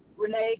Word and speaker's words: Renea: Renee Callery. – Could you spell that for me, please? – R-E-N-E-A.